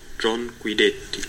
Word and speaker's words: John Guidetti: Ähm, laut Transfermarkt Aussprache, Moment. Jon Guidetti. John Guidetti. Genau der,